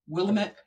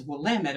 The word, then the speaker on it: Willamette: I love Willamette. I said it wrong. Oh my God. Willamette Valley, because it's not Willamette. It's Willamette if you're from there.